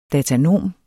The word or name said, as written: datanom